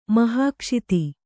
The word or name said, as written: ಮಹಾಕ್ಷಿತಿ